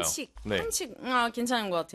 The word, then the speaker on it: 반칙: 칙이 조금 어려울 수도 있을 것 같긴 해요. – 칙. – 이게 뭐 칙칙 봄 봄. 마모사 노데츠 원넷 봄. – 네, 근데 칙 발음이 좀 어렵네. – 괜찮은 것 같아요. – 반칙. – 반칙. – Like chick. Chick with a chick.